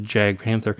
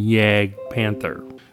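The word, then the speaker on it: Jagdpanther: And I have been saying Jag Tiger and Yag or Jag Panther. The J is a Y, basically. – Yeah.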